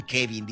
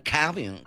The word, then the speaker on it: Kevin: No dicen Kevin, dicen Calvin. Que sé cómo le hacen.